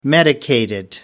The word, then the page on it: medicated: American English